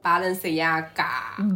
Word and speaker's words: Balenciaga: 哎，巴黎世家到底怎么读？Balenciaga。 – 巴黎世家。 – Balenciaga。